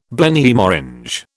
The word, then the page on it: Blenheim Orange: British English